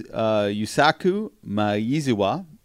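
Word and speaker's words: Yusaku Maezawa: Well, it turns out his name is Yusaku Maizuwa.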